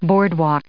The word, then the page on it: boardwalk: noun